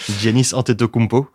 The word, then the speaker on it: Giannis Antetokounmpo: Giannis Antetokounmpo.